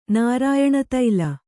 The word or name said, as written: ನಾರಾಯಣತೈಲ